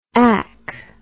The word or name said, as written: AC